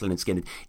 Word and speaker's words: Lynyrd Skynyrd: is nine minutes four, um, and Tuesday's Gone on the original uh, debut, pronounced Lynyrd, pronounced Lynyrd Skynyrd, is seven and a half minutes.